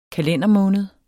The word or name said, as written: kalendermåned